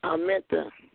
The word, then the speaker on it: Armentha: A-R-M-E-N-T-H-A. Amenta.